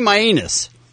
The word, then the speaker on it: Mainus: And the fighter's name, I'm going to spell it for you, is Danny Maianus. M-A-I-N-U-S. Maianus.